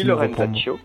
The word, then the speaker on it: Lorenzaccio: c'est bien ça, si je prononce bien oui, Lorenzaccio.